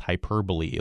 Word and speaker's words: hyperbole: I don't remember really seeing hyperbole spelled in a way that makes it more obvious that it's pronounced hyperbole. Like I don't see it spelled hyper b-o-l-e-e or b-o-l-e-y or b-o-l-i-e.